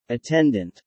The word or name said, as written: attendant